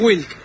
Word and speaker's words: Wick: Ni hablar, pero el apellido es WLK, así se escribe. – O sea, quedamos en Wick. – Se pronuncia Wick, exactamente.